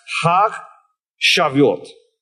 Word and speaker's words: Chag Shavuot: In Hebrew, it is pronounced Chag Shavuot.